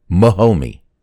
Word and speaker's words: Mahomey: Now, it's spelled Mahomey. So say that quick.